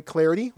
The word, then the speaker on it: Clardy: You can follow me on Twitter at Troy Clarity. Last name is spelled C-L-A-R-D-Y.